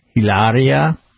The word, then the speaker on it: Hilaria: Yes, and I do believe his wife's name is pronounced Hilaria.